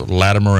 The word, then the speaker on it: Latimer: Yeah, so like one couple that we've talked about before was Latimer and Ridley. Am I saying that right? – That's right.